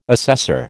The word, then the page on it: assessor: American English